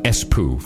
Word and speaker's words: Espoo: But we're going to pronounce it Espoo from now on.